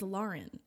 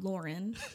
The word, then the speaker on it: Lauren: My name is Lauren. It's not Lauren. – But it's spelled like Lauren. – No. A-U says aw.